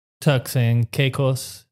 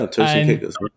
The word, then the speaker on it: Turks and Caicos: Turks and Caicos. Is that how you mm-hmm. pronounce it? Yeah, Turks and, and Caicos.